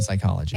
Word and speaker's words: psychology: The P is silent in psychology.